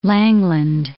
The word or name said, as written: Langland